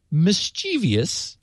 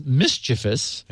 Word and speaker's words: mischievous: I noticed that you often say the word mischievous, which he actually notes is mischievous, with four syllables. – Yeah. – As if there were an I after the V. That's because you're an Okie. – And I thought, mischievous. Don't people say that? – No, you're an Okie.